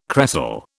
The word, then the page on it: cresol: British English